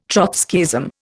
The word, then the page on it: Trotskyism: Indian